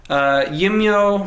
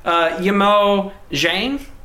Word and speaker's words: Yimou Zhang: and I'm going to butcher this, Yimyo... Yimyo? No, yep. – It's definitely not it. Yimou Zhang.